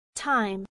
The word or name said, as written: time